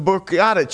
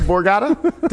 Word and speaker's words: Borgata: It seems that January 4th to January 8th, the Borgata Championship event is coming. – What? – The Borgata? Did I say it wrong? – You fumbled, but you got it.